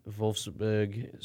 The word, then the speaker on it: Wolfsburg: Wolfsburg, switching into a nice German accent there. Yes. The official team of... – Oh, yes. I'm a worldly host. Wolfsburg.